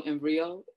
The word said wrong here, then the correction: Erivo: Um, Erivo, Cynthia Erivo.